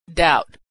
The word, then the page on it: doubt: d